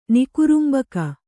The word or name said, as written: ನಿಕುರುಂಬಕ